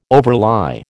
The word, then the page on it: overlie: American English